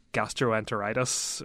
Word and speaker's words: gastroenteritis: gastroenteritis.